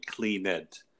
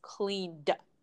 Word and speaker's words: cleaned: So a person learning English, making a mistake, would pronounce it probably clean it. – That is not correct. – Clean. Right. Yeah, that's not correct. This verb is going to have a D sound in the past tense. So you can imagine that cleaned, the pronunciation cleaned, imagine that it's spelled C-L-E-A-N-D.